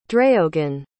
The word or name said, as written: dreogan